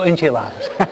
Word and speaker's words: enchiladas: Mami dice, tacos, tortillas y enchifladas. Okay. ¿Cómo? Siempre lo digo mal, ¿no? Tacos, tortillas, enchifladas. Ok. Enchifladas. Oh, enchiladas. Siempre lo digo mal.